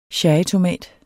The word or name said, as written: cherrytomat